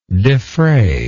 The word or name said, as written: defrayer